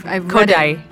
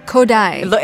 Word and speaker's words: Kodály: I've Kodály. – Kodály.